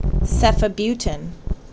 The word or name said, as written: Ceftibuten